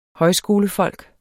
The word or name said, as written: højskolefolk